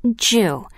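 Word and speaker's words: ju (十): 10 is considered a good number in Japan, because it is pronounced ju, like the Japanese word for enough.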